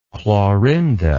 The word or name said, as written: Clorinda